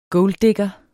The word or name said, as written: golddigger